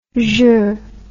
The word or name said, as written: jeux